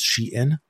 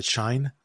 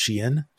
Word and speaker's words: Shein: Like I always called it Shine, and many people call it Shine, but it's actually pronounced Shein.